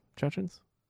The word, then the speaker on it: Chechen: And then people are like, well, but then there's these, like, Chechens? What's the difference? – Chechens and Chechens are the same thing. I think Chechen is the proper way to say it.